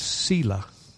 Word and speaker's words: Selah: There is a little word all through the Psalms that is pronounced Selah.